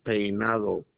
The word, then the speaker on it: Peinado: My last name is Peinado. P-E-I-N-A-D-O. – Okay, just to confirm, your first name is Josea, which is spelled J-O-S-E.